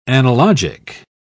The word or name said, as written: analogic